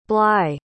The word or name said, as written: bligh